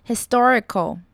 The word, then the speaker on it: historical: Historical 跟 history 两个重音位置是不同的。 Historical Historical 再来看到 figure Figure 呢，其实它准确的发音呢，G-U-R-E 那边会有点点 E 的音，看音标就会知道。